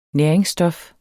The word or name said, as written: næringsstof